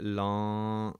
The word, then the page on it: lava: Punjabi